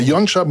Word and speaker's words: Yonca: Y-O-N-C-A. Das ist wichtig. Ben kann man sich gut merken, aber Yonja mit Y und dann ein C. Also wenn es Ben Yonja, aber nur mit C-A ausgesprochen. – Mhm. – So, durch Zusatz geschrieben, aber ausgesprochen anders.